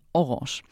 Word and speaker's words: Orange: Also wir haben Orange an Orange. Also wir schreiben das O-R-A-N-G-E. Und das ist super interessant, dass wir den Nasal von Orange mit rübergeholt haben.